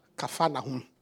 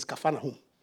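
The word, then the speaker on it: Kfar Nahum: What we call Capernaum, the Jewish guy says it's called Capernaum, not Capernaum. We say Capernaum. But the right pronunciation is Capernaum. Kafa Nahum. Kafa means a village. Nahum means rest. A village of rest. That's what it means.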